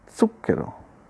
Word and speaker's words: zucchero: Lo stesso accade quindi anche a molti italiani con la parola appunto zucchero, che si pronuncia con la zeta sorda, come dicevo.